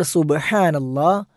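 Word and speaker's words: സുബ്ഹാനല്ലാ (سبحان الله): അപ്പോൾ സുബ്ഹാനല്ലാ, അല്ലാഹു അക്ബർ, സുബ്ഹാനല്ലാ എന്നത് ഹാ എന്ന ഉച്ചാരണം നമ്മൾ ശ്രദ്ധിക്കേണ്ടതാണ്. പലപ്പോഴും നമ്മൾ പ്രാർത്ഥനാ പഠനം എപ്പിസോഡിൽ ആവർത്തിച്ചു വരുന്ന ഒരു ഉച്ചാരണമാണ് ഹാ എന്നുള്ളത്. പല പ്രാർത്ഥനകളിലും ഹാവും ഐനും കടന്നു വരാറുണ്ട്. ആ ഹാവിൻ്റെ ഉച്ചാരണം നമ്മൾ ശ്രദ്ധിക്കണം. തൊണ്ടയുടെ മധ്യഭാഗത്ത് നിന്നാണ് ഈ അക്ഷരം പുറപ്പെടേണ്ടത്, സുബ്ഹാനല്ലാ എന്ന്. ഇത് ധൃതി കൂട്ടി തിരക്കിട്ട് ചൊല്ലേണ്ട ദിക്കറല്ല.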